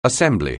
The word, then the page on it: assembly: anglais